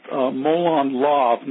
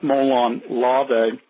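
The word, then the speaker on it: Molon Labe: It's actually pronounced Molon Lav, not Molon Lave. And there are a lot of people that mispronounce that.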